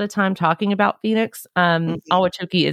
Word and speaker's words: Ahwatukee: I actually kind of Googled how to say that, and that's how it's pronounced as far as I can find out, but it's spelled A-H-W-A-T-U-K-E-E, Ahwatukee.